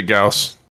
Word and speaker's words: Gauss: The Gauss Rifle, however you want to pronounce it. – Gauss. – It's pronounced it Gauss. – Yeah. It's regional.